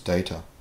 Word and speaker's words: Data: It's pronounced data. Oh, you called me data. What's the difference? One is my name, the other is not.